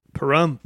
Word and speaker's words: Pahrump: Pahrump.